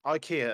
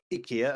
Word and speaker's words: IKEA: IKEA, as we say here in the English speaking world. IKEA, as they say in the IKEA adverts, and probably in Sweden.